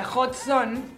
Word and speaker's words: Hot Zone: Muy bien pronunciada, con J, Hot Zone.